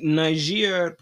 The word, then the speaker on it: Niger: And we are going to start by talking about the coup in Niger, which I've recently learned is the pronunciation for it.